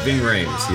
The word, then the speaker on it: Ving Rhames: Ving Rhames? – Yes, Ving Rhames, yeah.